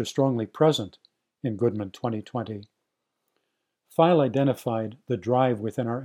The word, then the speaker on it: Pfeil: So what did file, that's spelled P-F-E-I-L, what did Fred File identify as underlying the problems he foresaw?